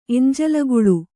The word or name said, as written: ಎಂಜಲಗುಳು(ೞ್)